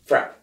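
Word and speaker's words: FRAPH: They didn't pronounce the H, and there was a reason for that. Because frappe, in French, frappe, you smack somebody.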